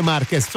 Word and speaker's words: Márquez: No, voi pronunciate Márquez. – Noi diciamo, tutto il mondo dice Márquez. – Dobbiamo dire Márquez.